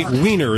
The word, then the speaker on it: Weiner: Anthony Weiner is how it's pronounced.